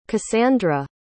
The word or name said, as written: kassandra